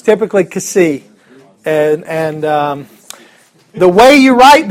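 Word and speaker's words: kasi (ξ): The next one is the letter, well, I would pronounce it kasi. And that this is one of the harder ones to pronounce. If you think of how do you pronounce the letter X? X. We'll take the e off and just go ks. That's the sound. Ks.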